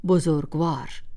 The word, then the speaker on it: بزرگوار: این کلمه را باید به همین صورت تلفظ کرد، ولی فارسی زبانان افغانستان گاه آن را بزرگوار تلفظ می کنند و از آن سوی نیز بسیاری از مردم ایران آن را بزرگوار می گویند. ولی هیچ یک از این اشکال درست نیست و درست همان بزرگوار است، مثل هفتوار، ماهوار و راهوار.